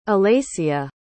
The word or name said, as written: alaysia